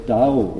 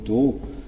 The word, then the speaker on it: Dao: Das berühmteste ist das Wort Dao oder Do, ja, japanisch ausgesprochen Do.